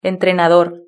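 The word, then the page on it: entrenador: Spanish (Spain)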